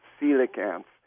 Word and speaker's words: coelacanth: Um, it, it's spelled really oddly. C O E. Um, I gotta look it up again. Even it's it's just you wouldn't expect it, but that's how you pronounce it, coelacanth.